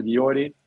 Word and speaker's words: Jordi: we usually say, if you get to England, they say like Jordi, but it's, yeah, we just pronounce it Jordi.